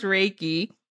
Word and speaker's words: Reiki: It's actually pronounced Reiki.